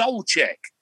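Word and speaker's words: Souček: And we had some, like, my girlfriend's there and his name is pronounced Soulcheck.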